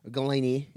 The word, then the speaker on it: Golini: You know, Dr. Jeff is, Galoni is his last name, Galini, if you want to pronounce it, G-O-L-I-N-I.